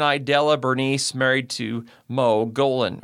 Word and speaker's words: Gulden: From there we go to Douglas Leroy Golden, last name is spelled G-U-L-D-E-N.